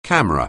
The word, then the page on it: camera: anglais